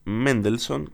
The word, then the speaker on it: Mendelssohn: Tenemos para esta ocasión dos piezas de Astor Piazzolla y una de Félix Mendelssohn, que me corregime si lo dije bien. – No, está bien dicho.